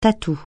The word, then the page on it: tatou: francais